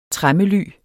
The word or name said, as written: tremmely